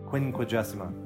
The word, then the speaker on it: Quinquagesima: Quinquagesima. That's how you pronounce this Sunday, if you're curious.